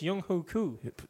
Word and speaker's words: Younghoe Koo: Younghoe Koo.